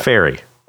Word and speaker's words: ferry: How do you say the first one? Ferry. Say the second one. Fairy. Fairy.